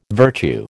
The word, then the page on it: virtue: American English